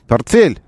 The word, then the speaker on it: портфель: Портфель, так это произносится.